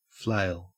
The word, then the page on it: flail: Australian English